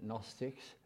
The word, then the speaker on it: Gnostics: Now that's spelled with a G, Gnostics. It's from the word that you can familiar with, agnostic.